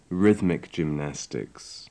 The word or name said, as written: rhythmic gymnastics